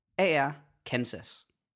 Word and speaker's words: Arkansas: Det udtales Kansas. – Mm. – Men byen Arkansas staves AR Kansas. – What? – Men så fordi der kommer AR på, så bliver det Arkansas.